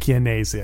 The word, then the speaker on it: Chianese: It's Chianese. And I looked it up, how to pronounce it. – Is it Chianese? – No, it's definitely a hard K at the beginning. – Oh, Chianese? – Chianese, I think that's what it is.